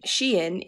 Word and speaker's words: Shein: Shein is a fast fashion brand. It is pronounced Shein, as it began as She Inside, which I don't know what that means.